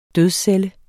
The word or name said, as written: dødscelle